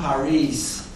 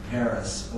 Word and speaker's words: Parijs: Philippe von Paris, he's Flemish, from Belgium. His last name, P-A-R-I-J-S, is pronounced Paris, not Paris, or, and it's the I-J is just a Flemish construction.